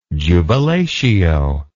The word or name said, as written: jubilatio